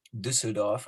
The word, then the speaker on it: Düsseldorf: Düsseldorf. I can just. Do the fucking proper German pronunciation.